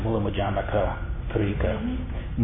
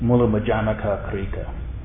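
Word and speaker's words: Mūlamadhyamakakārikā: But, how do you pronounce that? – Well, I pronounce it Mulambajamaka, Karika. Mulambajamaka, Karika.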